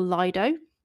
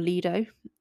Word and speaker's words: Lido: We also went to Bristol Lido, or Lido. Apparently that's how you say it, but I know everyone I know says Lido, which a Lido, if you're not familiar, is like a swimming pool, basically.